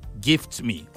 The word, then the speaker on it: GeeftMe: It's an opportunity for me to just say, just in case you're already wondering or searching for GiftMe, it's spelled GEEFT. It's not GIFT. So it's GEEFTME. GiftMe.